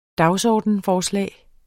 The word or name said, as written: dagsordensforslag